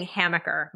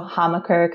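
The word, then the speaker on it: Hammacher: It's interchangeable. Okay. It's a German name. So Hamaker, kind of a softer A, but it's also based in Chicago, where we love to pronounce our A's. So I think a lot of people, it's very interchangeable.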